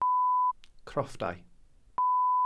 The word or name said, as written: crofftau